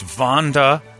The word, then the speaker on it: Vandalize: It's Vandalize. Brady, I will accept no other pronunciation. – Okay. – Since it's spelled Vonda, as in Vonda Shepherd, and lies, as in you shall not lie with that man. Biblical.